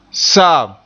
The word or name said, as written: saam